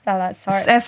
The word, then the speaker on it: sarcoidosis: Sarcoidosis.